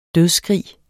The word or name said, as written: dødsskrig